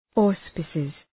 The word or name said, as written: auspices